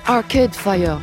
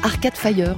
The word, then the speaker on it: Arcade Fire: Les anglo-saxons disent Arcade Fire, qu'on prononce Arcade Fire dans nos contrées, et ils nous pardonneront sans doute, puisque le groupe est né et vit toujours dans une ville en partie francophone.